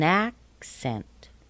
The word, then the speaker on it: accent: So our nouns and adjectives are pronounced da-da, and our verbs are pronounced da-da. Listen to the difference. An accent. Accent. But when we use the verb, we say to accent.